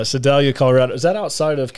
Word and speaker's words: Sedalia: Well, without giving too much away too soon, DK is from Salida. Is that how you say it? Sedalia.